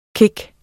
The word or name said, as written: kick